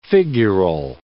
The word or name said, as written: figural